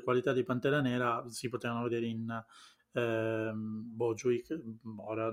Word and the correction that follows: Chadwick Boseman: non la pronuncia, ovviamente Chadwick Boseman.